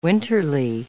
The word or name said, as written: winterly